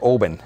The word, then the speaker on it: Oban: Just, it's the little port town that runs you off to pick a ferry, and so we're going to go to Oban, which is how it's pronounced, by the by.